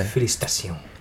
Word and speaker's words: félicitations: Félicitations. Félicitations, yeah. – Félicitations.